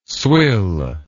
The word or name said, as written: swill